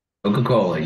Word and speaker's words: Koch: That's how we pronounce it, right? – Coca-Cola. You got it right.